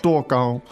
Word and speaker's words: 多高: 多高,多高。 – 多高。There you go. Let's repeat that a few times. It basically means how tall. – Yeah. 多高。多高? – Two first tones, right? – Correct. – 多高?